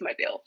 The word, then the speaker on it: Mobile: Mobile? I think it's Mobile. That's how you pronounce it.